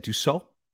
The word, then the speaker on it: Dussault: So we did it online and we got the draw, and Phil Dussault, how do we say it? – Dussault. – Okay. So like French, I took French.